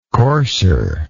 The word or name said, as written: coarser